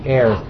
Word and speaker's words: heirs: God said to Abraham, I'm going to give your offspring something, and they'll be the heirs. Now, don't confuse that with H-A-I-R. That's hair. That's pronounced differently.